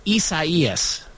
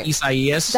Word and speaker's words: Isaias: It's spelled almost like, it's almost spelled, it's spelled similar to Isaiah, but it's pronounced Isaias.